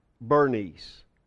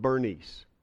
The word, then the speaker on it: Berenice: He arrived, no doubt, with an entourage, but that entourage included one Berenice. Her name is also pronounced Bernice. And that's how we will pronounce it from now on, Bernice.